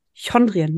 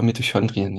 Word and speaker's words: Mitochondrien: Mito, habe ich das richtig ausgesprochen? – Ja, ich spreche es immer falsch aus, das möchte ich vorweg sagen. Ich sage immer Mitochondrien, das ist aber der sächsische Akzent, der dann noch äh, aus meiner Kindheit äh, in mir steckt.